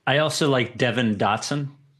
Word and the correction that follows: Devon: My first mispronunciation of the draft of the 2020 draft. – He's maybe the fastest player in the draft. – Yeah, you got Vecini right, and you didn't get Devon.